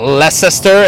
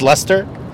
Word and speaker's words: Leicester: It's not Leicester. It's Leicester.